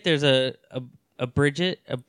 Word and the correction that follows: Brigitte: Brigitte in every game.